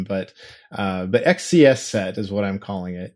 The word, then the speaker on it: XCSSET: So let's start with this new Mac malware, which is called XSET or XCSSet or XSET. Yeah, I pronounce it XCS set. Um, it's spelled XCSSET in all capital letters.